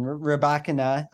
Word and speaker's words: Rybakina: And I am also going to talk about, I have an article out on Rybakina, I pronounced it right, Ostapenko.